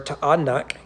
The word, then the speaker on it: Taanach: Verse 27, Manasseh did not drive out the inhabitants of Beth Sheen, so house of Sheen, and its villages, or Taanach, because you got to pronounce those vowels,